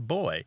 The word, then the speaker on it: buoy: Almost everyone there pronounces the word B-U-O-Y as boy, although some say it as boy, almost as if there's a W after the B, although it's less common. And many Americans, although not all Americans, say buoy, and some say boy.